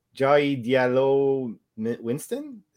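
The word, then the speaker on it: Jahi Di'Allo Winston: Yeah, Anthony Mackie and I'm gonna butcher his name, Jai Diallo Winston. – Yeah, you got it. – Okay, sound close enough.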